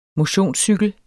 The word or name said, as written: MOTIONSCYKLER